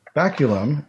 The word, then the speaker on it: baculum: So this word is baculum. – Okay. – Baculum. Baculum is spelled B-A-C-U-L-U-M, and it is a noun.